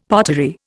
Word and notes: pottery: Indian

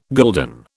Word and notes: gulden: British English

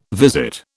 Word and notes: visit: British English

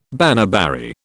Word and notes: baneberry: British English

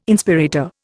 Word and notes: Inspirator: Indian

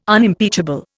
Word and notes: Unimpeachable: Indian

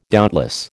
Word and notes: doubtless: American English

